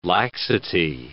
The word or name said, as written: laxity